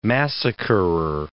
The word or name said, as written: massacrer